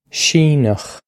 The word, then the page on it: Síneach: Irish